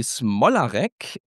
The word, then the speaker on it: Smolarek: Und da habe ich in Polen gelernt, dass das bei uns in Sachsen ausgesprochen Abby Smolarek nicht Abby Smolarek ist, sondern so wie diese Kinder immer geschrien haben, Smolarek!